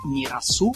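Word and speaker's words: Mirassou: que es un vino tinto 100% Pinot Noir que se llama Mirazú. Mirazou se escribe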